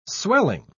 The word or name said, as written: swelling